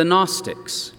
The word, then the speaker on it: Gnostics: and it was called the Gnostics. The word is a Greek word. It's spelled, interestingly, G-N-O-S-T-I-C, the Gnostics.